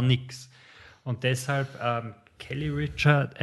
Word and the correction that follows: Reichardt: äh, Reichardt, glaube ich, spricht man sie aus.